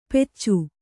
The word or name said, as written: ಪೆಚ್ಚು